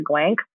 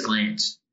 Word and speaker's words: Glance: I know Josh Glance, and I think it's, oh my God, I think it's Glance. – As in C-E, like you just say it like a glance of the eye?